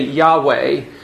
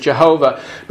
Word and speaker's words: Yahweh: We say Yahweh, The Germanic pronunciation is Jehovah.